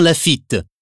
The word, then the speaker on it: Lafitte: L-A-C-A-R-R-A-U. – 2. Bonjour, je m'appelle Benjamin Lafitte. Mon prénom est B-E-N-J-A-M-I-N. Et mon nom est L-A-F-I-T-T-E.